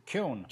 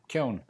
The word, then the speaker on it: Kuhn: Kuhn. Imagine that it rhymes with moan and groan and bone and stone. – And stick a K on the front. Kuhn.